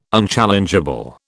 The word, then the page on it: unchallengeable: British English